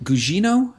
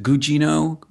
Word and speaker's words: Gugino: Is it Gugino? Is that how you pronounce her last name? – Yeah. – Is it hard G's on both? Is it Gugino? Gugino? – Yeah. – I think it's probably Gugino.